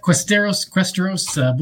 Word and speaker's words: Questeros: How do you pronounce it, actually? Questeros? – Close, yeah. Questeros. Both roll off the tongue about the same.